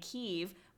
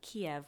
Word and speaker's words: Kyiv: The big, big story was that, according to Taylor, one of his staffers was in a restaurant in Kyiv, which is apparently the Ukrainian pronunciation of Kiev, which I didn't know. Okay. Kyiv.